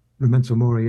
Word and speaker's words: Memento Mori: I believe this is the second track, Memento Mori, if I'm s pronouncing that correctly. – Memento Mori, yes.